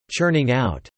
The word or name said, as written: churning out